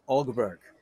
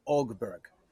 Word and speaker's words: Åberg: Ågberg, like A-U-G? Ågberg? – It's almost like A-U-B-E-R-G, so Ågberg. It's like an O with a line over it. So it's like kind of soft sound coming in, but that's how you say it.